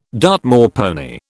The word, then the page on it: Dartmoor pony: British English